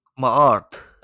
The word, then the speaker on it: Maat: But Maat, am I saying that right? – Yeah, I think so. Or Maat. – Yeah, oh, that's a great pronunciation there. Can you do it again for us? – Maat. – Oh, because it's spelled M-A-A-T, right?